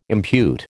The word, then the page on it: impute: American English